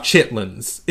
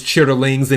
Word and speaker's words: chitterlings: Is the way that is the way it's supposed to be pronounced is chitterlings, and if you look at them, it's spelled out chitterlings, but usually people in the South say chitlins.